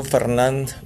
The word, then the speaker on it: Fernand: ya se nos apareció Fernand, no sabemos si es hombre o mujer, pero así se pronuncia, no se pueden traducir. No se deben traducir los nombres de los huracanes, así como está Fernand.